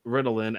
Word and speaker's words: Ritalin: This story's MacGuffin is a plaque medicine called Ritalin, accent on the second syllable. In real life, Ritalin accent on the first syllable is a commercial name for the psychiatric drug methylphenidate, used to treat attention deficit hyperactivity disorder.